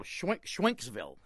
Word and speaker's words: Schwenksville: Did you say Schwenkville? – Yes. – How do you spell it? – S-C-H-W-E-N-K-S-ville. – Oh, Schwenksville.